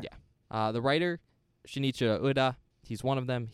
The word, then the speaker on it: Shinichiro: Shinichiro.